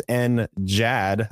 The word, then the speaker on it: NGAD: There's NJAD. That's a soft G. Same acronym, just a soft G, and that's the Navy. So, of course, they had to name it the same thing, which is a hard G versus soft G.